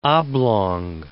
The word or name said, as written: oblong